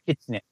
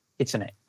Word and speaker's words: kitsune: Kitsune. Kitsune.